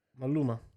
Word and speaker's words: Maluma: Jennifer Lopez, Owen Wilson, um, Manulo. How you pronounce that guy's name? Maluma. Maluma. Right.